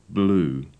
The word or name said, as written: blue